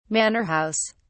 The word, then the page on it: manor house: British English